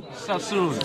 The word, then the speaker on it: Suisun: Did I say that right? – Suisun.